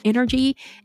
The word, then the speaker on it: Nrgy: Coach Energy. And energy is spelled N-R-G-Y, just in case you're listening to me and you're not looking at it on your web or online or possibly on YouTube. So NRGY.